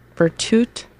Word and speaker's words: Virtute: You just finished listening to Virtue. I said that wrong. Virtute the Cat Explains Her Departure, I think, by The Weaker Thens.